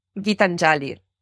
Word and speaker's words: Gitanjali: Gitanjali.